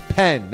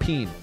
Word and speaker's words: pen: Remember, it's pen, not peen. Pen.